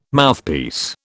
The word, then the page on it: Mouthpiece: British English